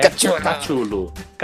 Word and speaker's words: Cthulhu: A melhor pronúncia é do Eric Cartman, do South Park, que é Cthulhu.